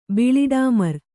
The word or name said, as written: ಬಿಳಿಡಾಮರ್